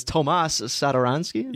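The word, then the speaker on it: Tomáš Satoranský: I believe it's Tomas Satoransky.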